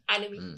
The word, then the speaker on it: anime: Anime. – Anime.